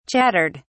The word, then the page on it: chattered: British English